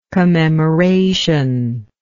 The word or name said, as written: commemorational